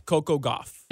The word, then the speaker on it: Coco Gauff: First major tournament for our girl Coco Gauff of South Florida. I hope I'm saying the name correctly. – I think you are. I said it like that. So yeah, it's Coco Gauff.